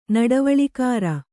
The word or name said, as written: ನಡವಳಿಕಾರ